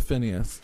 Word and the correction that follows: Fiennes: Joseph Fiennes. I always say his name wrong. Joseph Fiennes.